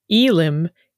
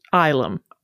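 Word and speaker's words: xylem: Y-L-E-M, Elim, can be pronounced Ilum.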